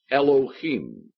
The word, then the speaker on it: Elohim: The Hebrew is pronounced Elohim, and the I am at the end of a Hebrew word means plural, more than one.